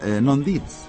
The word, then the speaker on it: non dit: Non dit. – Ah, muy bien. – Sí, non dit. Me parece que sin la S, sí, no se pronuncia.